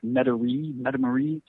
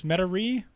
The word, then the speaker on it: Metairie: Like Metairie, something like that. Yeah, Metairie, I think it is. Metairie, which probably has some weird French pronunciation that I don't know in Louisiana, but yeah.